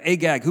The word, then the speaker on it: Agag: Yeah, Agag, who was one of the guys who came against Israel when they first came out of Egypt.